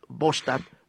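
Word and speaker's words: Båstad: Eso es Bostad, Suecia. Se escribe Bastad con B larga.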